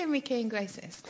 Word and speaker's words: Enrique Iglesias: All the organs are from how do you say Enrique Iglesias? – Like that.